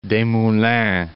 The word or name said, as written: Desmoulins